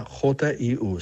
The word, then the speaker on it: Hu: La palabra Hu se escribe Hu. Se pronuncia Jiu. Suena así.